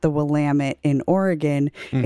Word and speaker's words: Willamette: I will come on this show and I will debate this right now that if you are in Oregon, it is pronounced Willamette. It's Willamette, damn it.